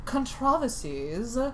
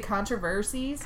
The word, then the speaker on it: controversy: Part three is actually kind of the controversies around... That's how they say it in Britain. – Oh, okay. – We say controversies. – Controversies. – They say controversy.